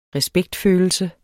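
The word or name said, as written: respektfølelse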